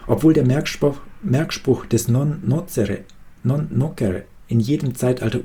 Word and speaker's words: nocere: Primum non nocere, vor allem nicht Schaden. Nocere, genau, das C wird hier so ausgesprochen wie ein K auf Latein.